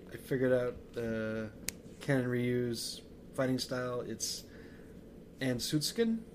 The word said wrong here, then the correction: Ansatsuken: Ansatsuken. Yeah, it's hard to pronounce. Okay. Yes. There you go. – You said it was a mouthful. – Yeah. – I guess it is. – It's like, more phonetically, it's Ansatsuken.